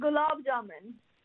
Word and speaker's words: gulab jamun: Sticky toffee gulab jamun. Am I saying that right? – Yes, nearly. Sticky toffee gulab jamun.